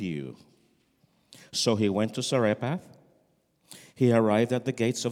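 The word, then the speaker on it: Zarephath: How do you say that in Georgia? Sarepath. Thank you. See, because where I come from is Sarepta. La viuda de Sarepta. But we're dealing with now the widow of Sarepath.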